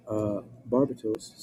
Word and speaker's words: Barbatos: It says, Barbatos, spelled like Barbados, but with a T.